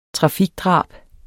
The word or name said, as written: trafikdrab